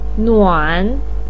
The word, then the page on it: nuan: 3